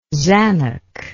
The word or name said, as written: zanuck